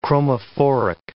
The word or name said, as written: chromophoric